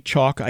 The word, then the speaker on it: Chalk: And speaking of rejecting a penal substitutionary atonement, on the list at Monergism is Steve Chalky. – Yeah, don't you just say chalk? I think it's just one syllable, chalk.